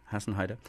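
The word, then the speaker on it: Hasenheide: And then Dieter Moebius' first solo album, Tonspuren, and a track called Hassenheide. Is that how you say it? Heide? – Yeah. – Hassenheide.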